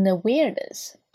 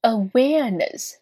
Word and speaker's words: awareness: Upon awareness. Awareness 名词呢是表示意识、认识的意思。 Awareness. 我们需要去注意一下这个单词当中呢，在美式发音当中的卷舌音呢是出现在 w 后面小口的 a 后面哈。 Awareness. 而并不是出现在尾音的 N-E-S-S 上面，千万不要读成了 awareness，是 awareness.